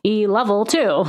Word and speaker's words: ELOVL2: It sounds like E level 2.